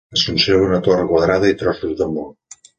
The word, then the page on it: quadrada: Catalan